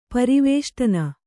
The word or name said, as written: ಪರಿವೇಷ್ಟನ